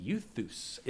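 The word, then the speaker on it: Euthus (εὐθύς): Euthus is the southern pronunciation of the Greek word for immediately.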